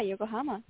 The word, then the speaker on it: Yokohama: And you are an actor, dancer, and singer based in New York City and a proud native of Yokohama, Japan. Did I pronounce that correctly? – Yeah, Yokohama.